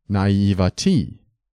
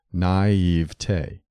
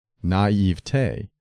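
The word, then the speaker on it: naïveté: Naivete combines naive and te, and standard word division calls for three syllables, naivete. Don't add a spurious fourth syllable. It is acceptable to stress the middle syllable, naivete, but the weight of authority favors the primary accent of the final syllable, naivete, which should rhyme with day, never with t.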